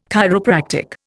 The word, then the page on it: chiropractic: Indian